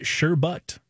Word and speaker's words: sherbet: Because when people started saying sherbet or sherbut or whatever, I was like, oh. I don't know what word they're saying. – Yeah. – the pronunciation guide I found actually said sherbet.